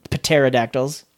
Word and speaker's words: pterodactyls: The band used their guitar to defeat the pterodactyls. It's hard to not say it the way it's spelled, even though it's not pronounced that way.